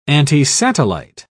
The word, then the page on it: antisatellite: British English